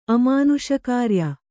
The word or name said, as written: ಅಮಾನುಷಕಾರ್ಯ